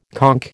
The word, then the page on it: conch: American English